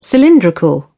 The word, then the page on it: cylindrical: British English